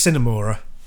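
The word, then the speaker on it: Sine Mora: But my favourite one, I played Simmora on the... Simmora? Help me pronunciation. – Yeah, I think it's Cinemora. Yeah. – Cinemora, there we go.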